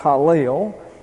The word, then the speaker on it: Helel: That's H-A-L-E-L. Oh, I'm sorry. H-E-L-E-L. Pronounced Hallel.